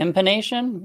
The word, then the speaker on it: impanation: Is that, am I pronouncing that right? – Yeah. Impanation. Yeah.